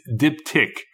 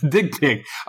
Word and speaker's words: Diptic: But it's spelled I call it Dick Pic. It's Dip Tick. – Okay. – I call it Dick Tick.